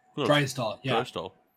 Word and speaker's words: Draisaitl: So you're telling me the T is silent? – Draisaitl.